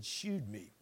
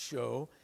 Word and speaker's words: shewed: That's just an archaic way of spelling the word "show." It's pronounced "show," and so if you read publicly, you'll want to read it correctly.